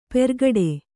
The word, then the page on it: ಪೆರ್ಗಡೆ: Kannada